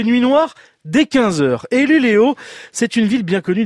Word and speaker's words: Luleå: Alors ça s'écrit Luleå, mais ça se dit Luleå. Hein, parce vous êtes totalement bilingue, vous. – Non, il y a le petit umlaut là, sur le A.